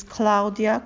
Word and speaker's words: Klaudia: My full name is Claudia Kowalski. – Please spell that. – K-L-A-U-D-I-A. And my last name, K-O-W-A-L-S-K-I.